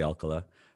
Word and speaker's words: Alcala: Is Alcala the proper, I'm a native San Diegan, I should know that. Is that the proper pronunciation? – I believe it is. I say Mission San Diego de Alcala, but I think that some people do say de Alcala, but I think they're wrong.